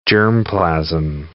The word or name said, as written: germplasm